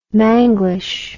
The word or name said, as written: manglish